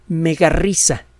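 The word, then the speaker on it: Megarhyssa: Eh, su nombre es Megarrisa.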